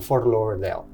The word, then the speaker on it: Fort Lauderdale: Era en Fort Lauderdale.